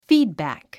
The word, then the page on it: feedback: fíːdbæ̀k; フィードバック